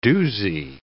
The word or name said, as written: doozy